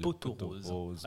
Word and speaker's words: pot aux roses: Alors, si on le prononce correctement, on est censé faire la liaison et dire le pot aux roses. Le pot aux roses. Bah, le poteau, évidemment.